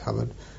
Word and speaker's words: Hobart: Spelt that way, pronounced Hubbard, and he used to take us out on lots of tactical exercises, and there were no sinecure at all.